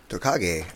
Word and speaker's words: Tokage: Tokage.